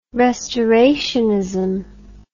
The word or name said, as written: restorationism